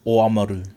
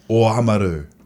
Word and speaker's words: Oamaru: Whereas O-A-M. – Omaru, yeah. – Can you say it? Give us a clear one. – O-Amaru. – O-Amaru.